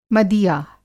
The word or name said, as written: मदिया